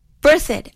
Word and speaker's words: Berthoud: Berthoud.